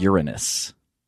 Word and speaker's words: Uranus: Uranus. And yes, it actually is supposed to be pronounced that way, I guess.